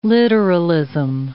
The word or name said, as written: literalism